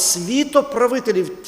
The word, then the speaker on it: світоправителів: проти світоправителів, наголос, світоправителів, ті, хто правлять світом.